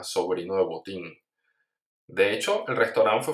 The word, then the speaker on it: Botín: Y en español, bueno, le pusieron simplemente un acento en la I y se llama Botín.